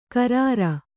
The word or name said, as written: ಕರಾರ